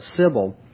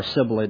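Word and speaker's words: Sibyl: And I think that probably refers to, or at least is an allusion to, the cult of Sybil, or Sibylle, depending on your pronunciation.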